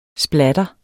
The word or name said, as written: splatter